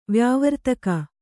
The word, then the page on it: ವ್ಯಾವರ್ತಕ: vyāvartaka